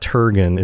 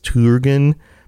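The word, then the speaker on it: Turgon: Like, it's Turgen, isn't it? It's not Turgen. It's Turgen, because the U is like the U in Turin.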